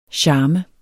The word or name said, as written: charm